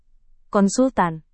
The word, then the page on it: konsultan: indonesian